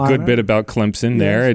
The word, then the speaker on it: Clemson: remember there's a P in Clemson. You don't see it. You don't spell it that way, but you'll get it right if you just spell it C-L-E-M-P-S-I-N, Clemson. It's correct. That is the correct way to pronounce it. A lot of people want to put a Z in there. They want to say Clemson. No, not like crimson.